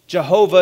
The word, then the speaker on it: Jehovah: He says, YHWH, that tetragrammaton, or Yahweh, it's sometimes pronounced. Jehovah is another way to pronounce it.